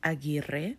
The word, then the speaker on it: Aguirre: Anyway, The War Priest by Anne Aguirre. You're like, why do you say it like that? Because I'm Spanish, okay? And it's A-G-U-I-R-R-E. So from my mind, it's Aguirre.